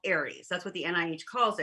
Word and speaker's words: Ares: And it's called Ares, A-R-E-S. And it's pronounced Ares as in the god of war.